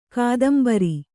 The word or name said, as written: ಕೊತ್ತುಂಬುರಿ